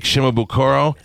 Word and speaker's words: Shimabukuro: This is Jake Shimabukuro. Am I saying that right? – Yeah.